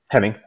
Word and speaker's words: Henning: Henning. – Is it Doug Hemming? – Henning. Sorry. – Henning. Yeah. I said it correctly then.